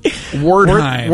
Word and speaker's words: Wertheim: Oh, John Wertheim.